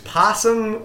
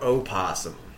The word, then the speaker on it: opossum: Possum or opossum? – Possum. – What's an opossum? – It's the same thing. – It's spelled with an O, and some people pronounce it because they're stupid.